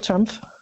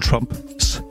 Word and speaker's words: Trump: Trines meget, meget søde mor er jo blevet repræsentant for en befolkningsgruppe, som har meget, meget svært ved at udtale Donald Trumps navn rigtigt. – Ja. – Hvor det her, det her F sniger sig ind.